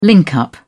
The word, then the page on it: link up: British English